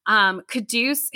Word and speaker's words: Quddus: Quduz, who I had to remember how you pronounced his name because he spells it Q-U-D-D-U-S.